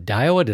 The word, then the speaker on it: Daiwa: I believe it's the Daiwa. The Daiwa, did I say it right? I'm close. Good. They're saying, yeah, you got it.